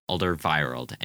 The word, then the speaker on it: Alderweireld: Alderweireld.